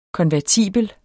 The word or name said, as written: konvertibel